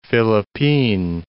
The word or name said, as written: Philippine Islands